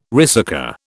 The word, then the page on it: ricercar: British English